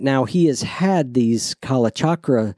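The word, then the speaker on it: Kalachakra: Kala Chakra? – Yeah, Kala Chakra.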